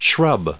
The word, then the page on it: shrub: noun